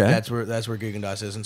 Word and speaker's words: Gigondas: So, well, so it turns out that these are pretty good, and we'll now, how did you pronounce Gigondas? – Gigondas. – Gigondas. – Gigondas. – Gigondas. Yeah. Okay, I'll remember that.